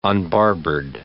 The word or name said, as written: unbarbered